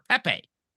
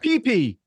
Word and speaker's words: pepe: Pepe. – There you go. – Okay. – There you go. – How do you say it? – It's not pee-pee, bud. – Like pepper. Pepe.